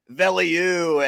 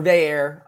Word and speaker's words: Veilleux: Let's get his name pronunciation correct for everyone, because when you look at it, everybody, I'm sure a lot of Pitt fans are saying, like, Veliu and Veyer and Velier or all kind of different things. So what does Noah Hiles know about the right way to say Christian Veyer's name? – It's Christian Veyer or Veyer. I think it's just Veyer.